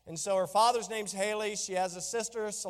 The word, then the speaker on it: Heli: It's actually spelled H-E-L-I. But it is pronounced Haley.